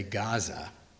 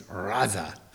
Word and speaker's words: Gaza: There's the evil of Bibi Netanyahu and Smotrich and Ben-Gavir and the genocide of the people in, now you're going to say Gaza. And 2 weeks ago, I would have said Gaza. But now I know it's Raza. That's how you say it in Arabic, Raza. But however you want to say it, Gaza, Raza.